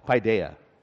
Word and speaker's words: paideia: When the paideia, I've been saying it wrong, paideia, isn't that like actually an Italian dessert or something? Yeah, paideia, wrong, paideia. That's the Greek and that's the child, okay?